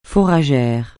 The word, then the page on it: fourragère: francais